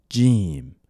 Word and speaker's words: Jim: To say good evening in Arabic, we say مساء الخير مساء الخير مساء الخير The fifth letter of the Arabic alphabet is Jim and it's pronounced J. J. Just like saying Jordan.